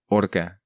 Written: Orca

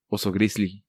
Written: Oso Grizzly

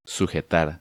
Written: Sujetar

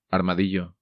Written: Armadillo

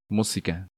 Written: Musica

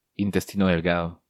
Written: Intestino Delgado

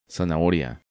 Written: Zanahoria

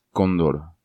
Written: Condor

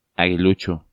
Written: Aguilucho

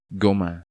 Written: Goma

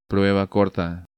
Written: Prueba corta

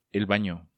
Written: El Bano

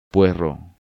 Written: Puerro

